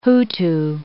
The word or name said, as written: Hutu